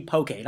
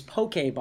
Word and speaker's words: poke: P-O-K-E, poke. Not pronounced poke, poke.